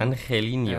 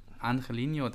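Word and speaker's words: Angeliño: auf Außen Ancelinch, An ja, sprachlich ist es heute unentschieden. Wir lassen es einfach mal mit den Aussprachen. – Angelino. Ähm, aber Angelino, Dankeschön.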